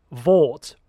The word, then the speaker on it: Vought: I did like the fact that Vought rhymes with bought, and it's all about the commercialization of these superheroes.